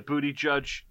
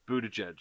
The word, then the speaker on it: Buttigieg: Did I miss anything with the booty judge? – Is that how do you say his name? Booty judge. – That's how I said it. Buttigieg. – I think it's Buttigieg. – Buttigieg.